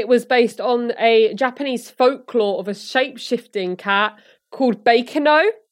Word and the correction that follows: Bakeneko: Bakeneko, I think is the pronunciation. – Bakeneko? – Yeah, that sounds better.